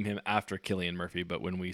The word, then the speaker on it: Cillian Murphy: No, it's spelled with a C, but it's Killian Murphy.